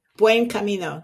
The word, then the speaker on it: Buen Camino: Buen Camino.